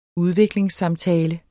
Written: udviklingssamtale

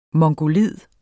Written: mongoloid

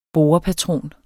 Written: borepatron

